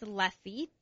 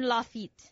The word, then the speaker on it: Lafitte: It's a name, but I think it's Lafitte.